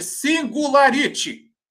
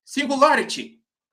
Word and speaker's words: Singularity: Aí, ó, o fala, o fala que eu te como, opa, fala como eu falo, ele disse o seguinte, Singularity. Singularity. Essa é, é a pronúncia certa.